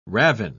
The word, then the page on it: ravin: British English